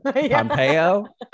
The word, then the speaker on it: Pompeo: Did Pompeo.